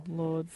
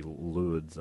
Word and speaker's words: Lourdes: I'm pretty sure the S is silent in Lord. – French, but the way we would bastardize it would be Lourdes, I'd say.